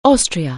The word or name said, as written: Austria